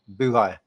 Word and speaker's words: Buhai: So I was like, okay, who's I can't even say her last name, but how did you say it? Buhai? – I believe Buhai. She's South African, right? – Buhai, yeah. – Yeah, I've known her since she was like, yeah. Buhai.